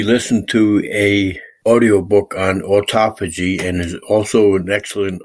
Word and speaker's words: autophagy: And you get extra points for pronouncing autophagy correctly, because a lot of people pronounce it autophagy. It's spelled kind of weird. It's like auto, like a car, phagy. P-H-A-G-Y, and it's a real buzzword in anti-aging medicine these days, because autophagy comes from the Greek word, which means self-devouring.